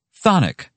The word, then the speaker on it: chthonic: Then I give you thonic, spelled, and I kid you not, C-H-T-H-O-N-I-C. – Sounds more like what a Dudley Moore character likes with his gin. – Well, the first C is silent. – And not to mention the first H.